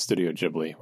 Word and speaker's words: Studio Ghibli: First update is that despite you saying it wrong 20 times, it's Studio Ghibli, which I know is... – That's what I said. It's Studio. – Sounds wrong, but mm-hmm. That's how they say it.